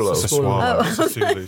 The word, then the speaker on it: Sassuolo: How is Sassuolo doing at the moment? – Sorry? – Sassuolo. Have I said it wrong? – Sassuolo.